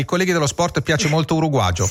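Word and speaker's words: uruguayo: De Mauro, il dizionario, registra un uruguagio che viene dallo spagnolo americano, si scrive uruguaio con la Y, ma in Uruguay si legge uruguagio questo modo di mettere la bocca.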